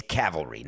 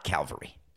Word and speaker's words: cavalry: It's cavalry, not Calvary. I said it wrong.